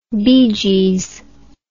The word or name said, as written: beegees